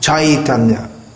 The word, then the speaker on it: Chaitanya: Actually, when we see AI in Sanskrit, that should be pronounced something like I, as in the word I, E-Y-E. So it should be pronounced Chaitanya.